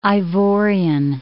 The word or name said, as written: Ivorian